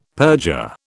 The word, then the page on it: perjure: British English